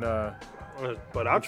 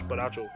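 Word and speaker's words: borracho: I don't know how to say drunk in Baracho, Spanish. It's Baracho. – Baracho? – Yeah. – Super Baracho Azul? – He actually got this mask.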